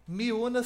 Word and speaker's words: Myuna: Miúna se escreve M-Y-U-N-A. Miúna. Y M-Y-U-N-A. M-Y-U-N-A. Tá certo. Miúna. Isso.